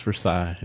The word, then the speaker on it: Versailles: It's pronounced Versailles.